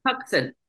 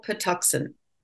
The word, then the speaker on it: Patuxent: You wrote in 2015, my editor at Little Pot, and I'm actually going to ask now, how do you say this word, Patuxent? – Patuxent. It's named for a Native American tribe, Little Patuxent. – Patuxent. Okay. I was close.